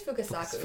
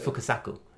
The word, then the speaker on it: Fukasaku: It was directed by, and again, I'm going to get this name wrong, Kinji Fukasaka. Fukasaku? – I think it's Fukasaku. – Fukasaku. – Yeah.